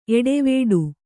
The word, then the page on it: ಏಟವೀಟ: Kannada